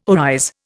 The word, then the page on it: arise: Indian